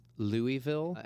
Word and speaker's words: Louisville: And it should be pronounced Louisville after King Louis, but we say Louisville.